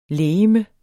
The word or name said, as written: legeme